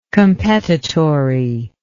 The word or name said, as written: competitory